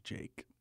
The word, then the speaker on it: JVKE: I believe it's pronounced Jake.